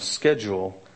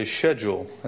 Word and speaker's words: schedule: I like the English pronunciation of schedule. They say schedule. A schedule sounds much better than schedule, but this is schism here for you if you're British.